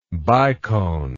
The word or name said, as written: biconically